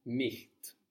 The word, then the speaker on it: nicht: The word for not is nicht. – Nicht. – Nicht. – Ich is spelled I-C-H.